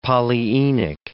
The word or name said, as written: polyenic